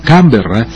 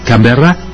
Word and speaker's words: Canberra: En pronunciación local sería el acento sobre la primera sílaba, Canberra, y en francés es Canberra con el acento en la última A.